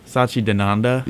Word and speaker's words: Satchidananda: Yeah, but like there's the proper pronunciation and then there's the Americanized pronunciation. Okay, and I couldn't do either. – Yeah, the Americanized is like. Satchidananda.